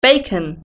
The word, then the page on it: bacon: British English